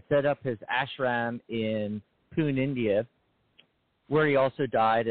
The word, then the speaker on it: Pune: He's from Pune. There's no R. Every time I look at that word, I see Pune. It's Pune.